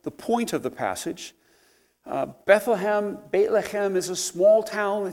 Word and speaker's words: Bethlehem: And the answer is in, pronounce it correctly, Bethlehem. You got to clear your throat if you want to pronounce Hebrew or German.